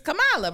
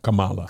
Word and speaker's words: Kamala: We put the emphasis on the second syllable. – Syllable. – Yeah, and they were like, no, no. – Also, you right. Early, I made that mistake because the wrestler was Kamala. No, Kamala.